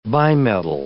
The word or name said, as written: bimetal